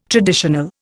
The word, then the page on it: traditional: Indian